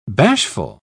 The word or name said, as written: bashful